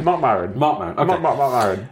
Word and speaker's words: Maron: Marc Maron, right? – Marc Maron. Yeah.